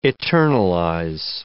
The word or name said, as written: eternalize